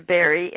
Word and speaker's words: Berry: Also, they can just go to Blog Talk Radio and do a search with my name, Linda Barry, and my last name is spelled B-E-R-R-Y.